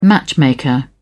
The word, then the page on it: matchmaker: British English